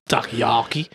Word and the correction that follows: takoyaki: Takayaki? Is that how you say it? – Takoyaki.